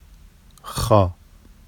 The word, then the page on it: xɑ: Vafsi